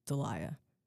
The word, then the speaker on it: Dahlia: My name is Dahlia. It's pronounced like the name Delilah, but without the second L. Dahlia.